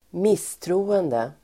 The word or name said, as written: misstroende